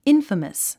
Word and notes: infamous: ínfəməs; インファマス

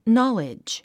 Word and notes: knowledge: nɑ’lidʒ; ナァレッジ

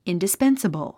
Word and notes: indispensable: ìndispénsəbl; インディスペンサボォ

